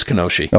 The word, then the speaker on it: Kenosha: It's Kenosha.